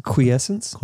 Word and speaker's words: quiescence: Quiescence. Yeah. Tectonic quiescence.